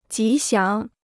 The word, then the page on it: 吉祥: jí xiáng